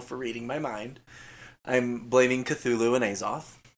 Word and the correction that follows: Azathoth: Azathoth? – Azathoth, oh my gosh.